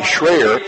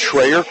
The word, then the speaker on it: Schroer: Kenzie Schroer, or I should say Schreyer, is the correct pronunciation. Kenzie, a fine junior performer, spelled S-C-H-R-O-E-R, pronounced Schreyer.